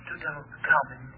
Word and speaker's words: Tutankhamun: It's pronounced Tutankhamun.